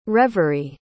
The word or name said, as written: reveree